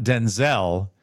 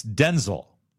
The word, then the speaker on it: Denzel: It's not Denzel. It's Denzel. You emphasize the first Washington.